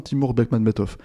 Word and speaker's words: Timur Bekmambetov: Timur Bekmambetov.